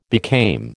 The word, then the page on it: became: American English